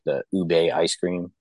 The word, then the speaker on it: ube: Ube, UBE, I think is how it's spelled.